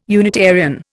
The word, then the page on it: Unitarian: Indian